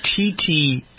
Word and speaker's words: Titi: Pierce, who appeared on the Ellen DeGeneres program, but Ellen did not pronounce her name T.T. Her name is spelled T-I-T-I, and she pronounced it according to the way it was spelled, not the way this lady wants it.